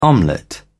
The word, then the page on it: omelette: British English